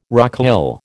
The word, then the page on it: rocaille: American English